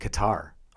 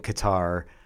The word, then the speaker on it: Qatar: They had a guy looking in a, you know, Western baseball hat, but he described how to say it, and there are three letters in Qatar that have no corresponding sound in English. So when you hear him say it, it's amazing. The R is rolled. And the k sound comes from a different part of your throat than what we use. Okay. But he said basically, for English speakers, Qatar is fine. Qatar is not how you say it.